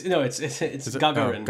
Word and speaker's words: Gagarin: What is it? Yuri Gagarin. – It's Gagarin. – Gagarin. – Yeah, it's Gagarin.